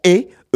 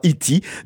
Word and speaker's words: E.T.: Poripou qui comprend baston, qui comprend Paris, qui comprend et E.T. Oui, ça s'écrit comme ça, mais ça se prononce E et non pas Iti.